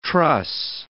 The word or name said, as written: truss